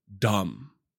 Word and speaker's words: Dam: Now, that sounds like dumb in the English language, but it's actually dam, you know, like a body of water in the English language, but in Afrikaans, you pronounce it as dumb.